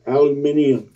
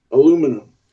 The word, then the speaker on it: aluminium: You know, when I was little, I could not say the word aluminum till I was like 15. I would always pronounce it aluminium. – That's how they say it over there. – I know. I found that out much, much later, that they decided in 1925 to make this pronunciation officially aluminum. – Okay. – But I couldn't get my mouth to say that. It always went to aluminium.